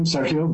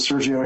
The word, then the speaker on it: Sergio: My name is Sergio Santaviago, and I appreciate the Spanish pronunciation of my name, Sergio, but we'll go with Sergio here just for convenience.